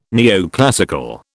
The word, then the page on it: neoclassical: British English